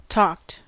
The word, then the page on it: talked: American English